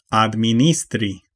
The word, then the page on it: diriger: France; /di.ʁi.ʒe/